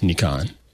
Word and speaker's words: Nikon: Yes, Nikon. Most people say Nikon.